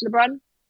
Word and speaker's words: Lebrun: So I'm on Instagram at Ams Lebrun, which is, should I spell it out?